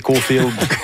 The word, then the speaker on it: Caulfield: Vous voulez avoir le chandail. Caulfield. C'est vous qui prononcez comme ça. Vous et Guy Carbonneau prononcez Caulfield.